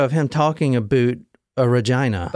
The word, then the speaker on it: Regina: The next stop was Regina, and that is how you say it, not Regina. It's Regina. I saw a video of someone saying Regina.